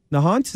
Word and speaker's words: Nahant: Nahant, is that correct? – That's correct.